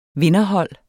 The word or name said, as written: vinderhold